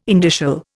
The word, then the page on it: indicial: Indian